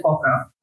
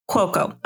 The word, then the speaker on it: Cuoco: Why can't I say her name? Cuoco? Is that it? – Yeah, Kaylee Cuoco. – Cuoco. Okay, thank you.